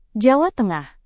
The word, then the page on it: jawa tengah: Indonesian